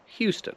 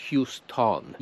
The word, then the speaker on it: Houston: I tutaj słyszę, że to O na końcu Houston to jest schwa. Houston. To nie jest Houston.